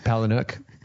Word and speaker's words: Palahniuk: Palahniuk.